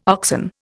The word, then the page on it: oxen: Indian